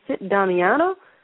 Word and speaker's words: Sit Damiano: I'm trying to find information on Sit Damiana. She said, you mean Sit Damiano? And, you know, because I was pronouncing it wrong, she didn't know what I was saying for weeks, and finally she figured it out, and I said, Sit means lady, right?